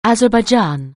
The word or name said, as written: Azerbaijan